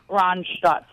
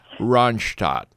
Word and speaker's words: Randstad: What is the Dutch pronunciation? – Randstad. – Randstad. All right.